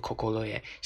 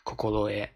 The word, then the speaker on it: 心得: 心得就是今天要讲的这个词啊，它写作心得，然后呢，它读作 kokoroe，kokoroe 啊，读作 kokoroe，写作心得，然后它的含义呢，也就是有心得的意思，当然也有经验、知识啊，非常和我们国内的这个汉语非常相似的一个词啊，它读作 kokoroe，写作心得，然后这个词呢，它要搭配一个句式去使用，非常基本的基础的一个句式，就是。